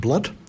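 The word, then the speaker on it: Blount: T-A-L-E-S. Spider Tales. And it comes to us from Jake Blunt. Spells his surname B-L-O-U-N-T. I think it would be pronounced Blunt.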